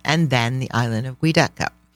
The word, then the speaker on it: Giudecca: But it's spelled G-U-I-D-E-C-A. – It's because Guido. – Right. – But sorry. – It's pronounced Giudecca. – Giudecca.